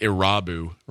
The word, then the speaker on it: Irabu: All I remember about him is that poor Harry Carey, near the end of his career, couldn't say his name. – Yeah. – He kept saying, Ibaru. Hideki.